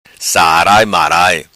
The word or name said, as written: Sarai Marai